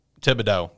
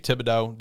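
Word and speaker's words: Thibodeaux: Thibodeau. – That's what I thought it was going to be. Thibodeau, defensive end out of Oregon.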